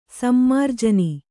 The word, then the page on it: ಸಮ್ಮಾರ್ಜನಿ: sammarjani